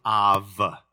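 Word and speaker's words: Av (אב): First of all, Abba, A-B-B-A, Abba is the Aramaic word for father. The Hebrew word for father is Av. It looks A-B, but it's pronounced as a soft B in Hebrew. It's pronounced like a V, Victor. So it's Av.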